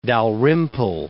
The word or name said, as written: Dalrymple